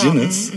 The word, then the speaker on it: Eugenides: Ähm, ist eine fiktionale Geschichte nach einem Roman von Jeffrey Eugenides oder Eugenides. Jeffrey Eugenides. Eugenides. Ja Stimmt, genau. Eugenides.